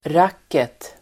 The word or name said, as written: racket